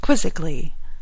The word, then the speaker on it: quizzically: C would make 9, but quizzically, quizzically, is more often pronounced quizzically, as if it were 3 syllables.